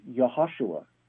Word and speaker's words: Yahushua: Joshua isn't a correct pronunciation of that name either, because isn't Joshua. It's Yahushua. – Well, okay. – Right number of syllables, and it starts with a J instead of a Y sound.